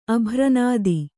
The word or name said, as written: ಅಭ್ರನಾದಿ